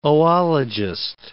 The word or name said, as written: oologist